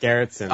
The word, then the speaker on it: Gerritzen: Gerritzen.